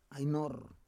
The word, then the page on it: ai nor: Roon